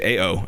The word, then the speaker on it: Ao: So Ao, I believe is his name, is just A-O. I believe it's pronounced Ao.